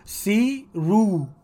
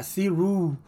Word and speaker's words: Ceeroo: Yo he escuchado que lo pronuncia C-Ru, como si en español se escribiera C-I-R-U. O sea, Siru...